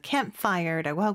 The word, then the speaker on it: campfire: C-A-M-P-F-I-R-E. – 네, 한 단어죠, 그렇죠? 그래서 campfire라고 하고요. 우리는 campfire, 다섯 개의 음절이지만 영어로는 두 개의 음절로 campfire 이렇게 발음을 합니다.